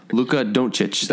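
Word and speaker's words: Dončić: What, can you pronounce that name again? – Luka Doncic. That's the correct pronunciation, yes.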